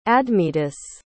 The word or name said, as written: admetus